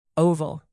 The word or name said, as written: oval